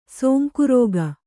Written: ಸೊಂಕುರೋಗ

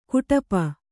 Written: ಕುಟಪ

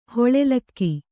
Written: ಹಾಲುಲಿಗೆ

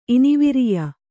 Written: ಇನಿವಿರಿಯ

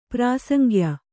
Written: ಪ್ರಾಸಂಗ್ಯ

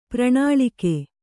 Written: ಪ್ರಣಾಳಿಕೆ

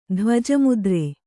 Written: ಧ್ವಜಮುದ್ರೆ

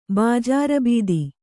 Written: ಬಾಜಾರಬೀದಿ

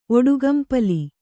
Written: ಒಡುಗಂಪಲಿ

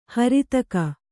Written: ಹರಿತಕ

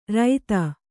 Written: ರೈತ

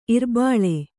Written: ಇರ್ಬಲ

